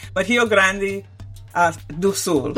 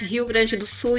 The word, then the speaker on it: Rio Grande do Sul: I don't want to butcher the Portuguese, but Rio Grande do Sul.